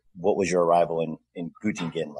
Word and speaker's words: Göttingen: Göttingen? – Göttingen.